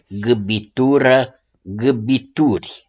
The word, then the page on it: GÂBITURĂ: GÂBITURI